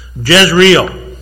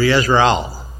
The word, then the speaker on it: Jezreel: Jezreel, first child. Actually, it's pronounced in the Hebrew, Yezreel.